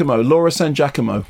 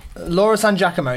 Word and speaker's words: Laura San Giacomo: Laura San Giacomo. – Laura San Giacomo, yeah.